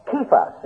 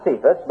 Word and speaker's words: Kephas: You will be called Kephas. It's not Cephas, but if you look into the exhaustive concordance, it is spelled with a K for pronunciation.